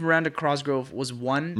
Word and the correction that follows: Cosgrove: Oh, Miranda Cosgrove. Oh. – Is that how you say her name? – Cosgrove. Well, that's how it's spelled. – Cosgrove. – Just the one R.